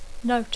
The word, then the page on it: note: English